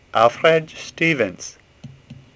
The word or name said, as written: Stevens